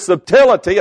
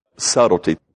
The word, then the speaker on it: subtlety: Paul warned that as the serpent beguiled Eve through his subtlety, and I know I always say that wrong, subtlety.